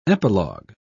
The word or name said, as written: epilogue